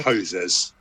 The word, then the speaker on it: Hosers: That's the right pronunciation, right? – Hosers.